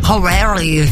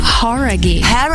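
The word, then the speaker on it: Jauregui: Horaghi, não é? – Horaghi. – Horaghi. Então a gente tava falando errado, cara.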